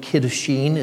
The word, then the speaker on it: Kiddushin: Kiddushin is how it's pronounced in Hebrew.